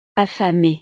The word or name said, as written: affamé